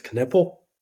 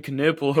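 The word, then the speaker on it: Knueppel: I think it's Knipple. I think that's how he said it one day.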